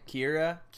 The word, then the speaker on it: Kaira: Her name's Kyra, I think is the way to pronounce it. K-A-I-R-A. – Okay. – Kyra, right? – Kyra.